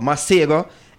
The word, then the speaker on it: Masego: Masego. M-A-S-E-G-O. Masigo.